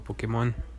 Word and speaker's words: Pokémon: Es Pokémon o Pokémon o Pokémon. – Eh, es Pokémon. – Ajá, Pokémon. Ah, de, hecho lleva, un acento en la E.